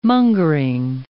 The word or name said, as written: mongering